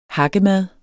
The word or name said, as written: hakkemad